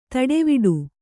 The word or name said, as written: ತಟವಟ